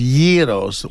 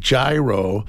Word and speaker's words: gyro: The proper pronunciation is Yeros, Y-E-E. But just a funny story, for years, people in Tucson started coming in and calling it gyro